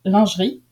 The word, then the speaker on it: lingerie: But if you want sexiness, I can tell you that the word lingerie, I think is how you say it in English, is pronounced lingerie in French,